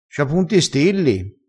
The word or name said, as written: stilli